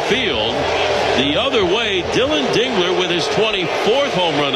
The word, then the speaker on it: Dingler: And you want to make sure you say it right. – Dingler. – Dingler. Get that right.